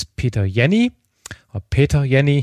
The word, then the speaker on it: Peter Jenni: Our guest is Peter Jenny, he's Swiss, so I should pronounce it in German.